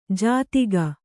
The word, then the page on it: ಜಾತಿಗ: jātiga